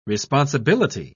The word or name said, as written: responsibility